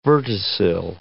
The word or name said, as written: verticil